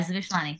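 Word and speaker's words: Zmishlany: Is the Z silent? – No, you pronounced it back in the beginning very well. – Zmishlani? – Yeah, Zmishlani. There you Zmishlani.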